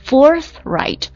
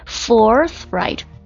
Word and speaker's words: forthright: forthright forthright 有一个咬舌音， forthright forthright，还记得这个单词的意思吗？